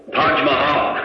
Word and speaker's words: Taj Mahal: Taj Mahal.